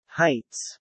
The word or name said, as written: heights